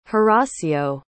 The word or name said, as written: horacio